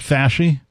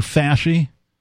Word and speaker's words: Fauci: I believe it's pronounced Fasci. – Yeah. – Dr. Fasci.